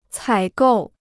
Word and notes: 采购: cǎi gòu

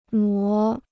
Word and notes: 挪: nuó

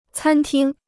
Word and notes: 餐厅: cān tīng

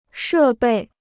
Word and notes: 设备: shè bèi

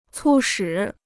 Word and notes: 促使: cù shǐ

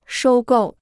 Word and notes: 收购: shōu gòu

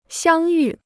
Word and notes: 相遇: xiāng yù

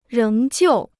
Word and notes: 仍旧: réng jiù

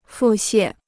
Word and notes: 腹泻: fù xiè

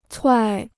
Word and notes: 踹: chuài

